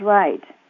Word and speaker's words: Wright: My maiden name is Wright, W-R-I-G-H-T.